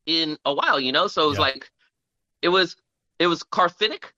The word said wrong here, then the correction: cathartic: It was cathartic.